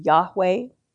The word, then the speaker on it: Yahweh: This, if it were pronounced by a Hebrew, would be Yahweh.